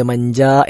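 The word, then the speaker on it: മഞ്ച (منجى): അവിടെ എജീമ് നീട്ടാതെ, എജീമിന് ശേഷം ഒരു അലിഫുണ്ട് മഞ്ച എന്നാണ്, അത് നീട്ടാതെ ലാ മൽജ വല മഞ്ച മിങ്ക ഇല്ല ഇലൈക എന്ന് ചില ആളുകളെങ്കിലും ഉച്ചരിക്കാറുണ്ട്. അത് തെറ്റായ ഉച്ചാരണമാണ്. ലാ മൽജ വല മഞ്ച മിങ്ക ഇല്ല ഇലൈക എന്നാണ്.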